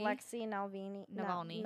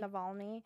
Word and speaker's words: Navalny: Alexei Navalny. – Navalny.